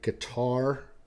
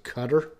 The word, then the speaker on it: Qatar: So I actually pronounced this country Qatar if you go to the Middle East, you'll hear them pronounce it "cutter."